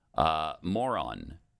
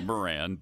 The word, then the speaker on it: Moran: Moron. It's Moran, but I'm going to pronounce it moron in this particular case.